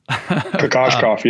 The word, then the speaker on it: Kokoskov: You've mentioned a couple, Steve Donahue, Igor, I always butcher the last name, Kokoskov. – Kokoskov, yeah, exactly.